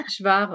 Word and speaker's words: Hvar: Je crois qu'on prononce, c'est H-V-A-R. Var, ouais parce qu'on était à Split à ce moment-là, donc c'est la grande ville qui est en face de l'île.